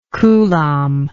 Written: coulombic

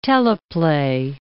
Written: teleplay

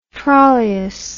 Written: trollius

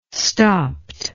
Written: stopt

